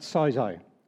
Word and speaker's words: sozo: Now that word save in the Greek is probably not pronounced right, but sozo, okay?